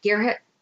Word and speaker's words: Gearhart: Gearhart. – Yeah. I don't, it's spelled differently than the way that people say it.